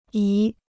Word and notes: 姨: yí

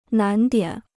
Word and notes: 难点: nán diǎn

